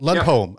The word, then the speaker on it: Lundholm: Lundholm.